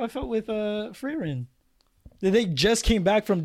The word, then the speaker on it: Frieren: Frieren.